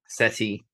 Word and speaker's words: Ceti: I'm not sure on the pronunciation, but it's CETI. The baby CETI. – SETI. Because SETI Alpha.